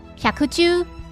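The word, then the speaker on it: 百獣: 百兽在日语里就念作 “hyakuju”，王在日语中可以念作英文字母 O。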